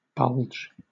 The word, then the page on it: bulge: British English